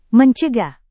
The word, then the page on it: mencegah: Indonesian